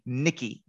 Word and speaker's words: Nikki: I, am saying it wrong. It's I go back and forth between Vicky and Nikki. It is actually Nikki, Nikki with an N.